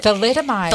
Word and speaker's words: thalidomide: Thalidomide.